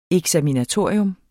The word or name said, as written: eksaminatorium